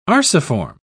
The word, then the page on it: arciform: British English